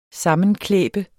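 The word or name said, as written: SAMMENKLÆBER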